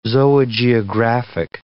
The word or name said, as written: zoogeographic